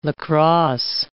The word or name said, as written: lacrosse